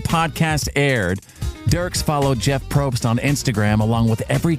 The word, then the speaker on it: Probst: Is it Jeff Probst? Yes, Probst. P-R-O-B-S-T.